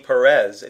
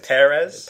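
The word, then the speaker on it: Pérez: Because the accent is on the first E? – I think so. – Instead of being Perez, it's Perez.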